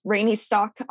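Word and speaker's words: Raini Stock: I'm just Rainy Stock on Facebook.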